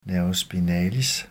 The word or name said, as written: n. spinalis